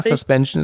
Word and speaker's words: Suspension: Ja, das schimpft sich, also ich weiß jetzt nicht, ob ich es richtig ausspreche, Body, also Suspension, ich weiß jetzt nicht, wie man es Englisch ausspricht. – Ja, Suspension ist korrekt, ja.